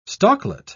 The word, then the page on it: stalklet: British English